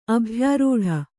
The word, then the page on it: ಅಭ್ಯಾರೂಢ: abhyārūḍha